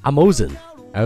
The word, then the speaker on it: Amazon: 我曾经听到一个人读作 Amazon，I was like what？